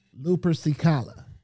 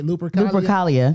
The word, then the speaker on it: Lupercalia: Lupercicala. Is that right? Lupercalia.